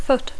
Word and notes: foot: English

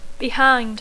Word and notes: behind: English

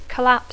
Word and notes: collapse: English

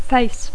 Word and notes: face: English

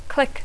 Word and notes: click: English

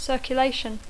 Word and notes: circulation: English

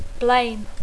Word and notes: blame: English

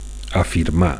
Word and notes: afirmar: Catalan

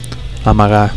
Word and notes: amagar: Catalan

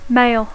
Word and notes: mail: English

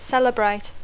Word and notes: celebrate: English

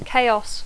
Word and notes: chaos: English

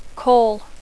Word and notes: call: English

